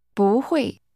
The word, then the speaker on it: 不会: 不会. 不会. – That's pronounced with first a rising tone, then a falling tone.